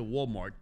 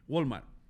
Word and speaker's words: Walmart: Bueno, no se dice Walmart, se dice Walmart.